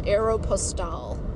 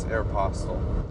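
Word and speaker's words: Aéropostale: All right, because I used to call it Aeropostale. – No. – Like airmail in French. That's... – No, it's Aeropostale.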